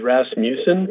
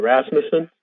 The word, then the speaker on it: Rasmussen: Yeah, I was going to say, I think the correct way to say it is Rasmussen, because I heard some people from Denmark say it that way, but I call it, I say it Rasmussen.